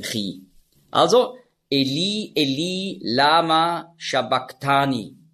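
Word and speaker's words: sabachthani: Und zwar kommt das daher, Matthäus schreibt ja Griechisch. Im Griechischen gibt es keinen Sch-Laut. Es gibt nur das S. Und da musst du den Sch-Laut mit einem S schreiben. Und das CH im Griechischen, das ist ja ein Buchstabe, das Chi, das hat man ausgesprochen so wie ein C hinten im Gaumen. K. Also nicht Shabbach, sondern Shabbak.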